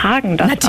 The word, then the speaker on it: Häagen-Dazs: Hallo, ich bin von Häagen-Dazs und unsere Eiscreme-Marke spricht man Häagen-Dazs aus, ohne den Umlaut, der wird im Skandinavischen nicht mitgesprochen.